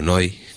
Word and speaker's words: Neu: Tipo Can, o Neu, o Neu, que es como se pronuncia en alemán, que son alemanes.